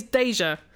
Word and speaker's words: Deja: I speak as an expert since my name is Deja. Pronounced like the French term déjà vu.